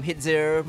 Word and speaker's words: Hidz: I'm Hidzir. Most of my friends call me Hidz. It's easier to pronounce.